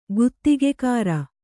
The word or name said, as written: ಗುತ್ತಿಗೆಕಾರ